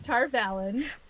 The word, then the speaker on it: Tar Valon: Tarvalin? – It is. I looked it up. It's Tar Valon.